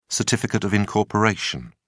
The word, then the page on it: certificate of incorporation: British English